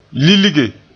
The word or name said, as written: lilge